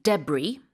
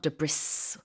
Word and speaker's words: debris: The pronunciation can also be debris. That's okay. But you don't pronounce the S. – Nope. – So it's not debris. It's debris or debris.